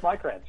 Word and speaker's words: Maikranz: Yeah, Eric Maikranz.